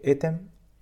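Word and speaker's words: Ethem: Y el autor se llama eh, Etem Alpaidin. un nombre un poco extraño, pero Etem, como e e E-T-H-E-M. Etem Alpaidin, tal cual como se pronuncia.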